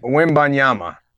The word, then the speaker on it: Wembanyama: Wembanyama.